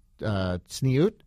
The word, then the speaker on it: tzniut: All of the outfits follow the Torah's laws of modesty, or tzniut. Is that how you say it? Tzniut? – Yeah, good job.